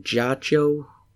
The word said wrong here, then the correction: Giacchino: Giacchino. Giacchino. Michael Giacchino, yes.